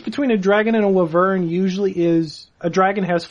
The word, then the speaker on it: wyvern: Google pronunciation says it's wyvern.